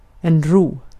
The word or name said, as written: huzur